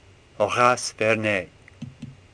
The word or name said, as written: Vernet